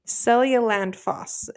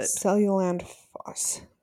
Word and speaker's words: Seljalandsfoss: Seljalandsfoss is how you pronounce it. – Seljalandsfoss. Okay. – So a J is like a Y. Seljalandsfoss.